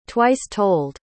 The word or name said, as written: twice-told